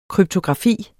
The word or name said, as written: kryptografi